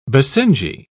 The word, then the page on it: basenji: British English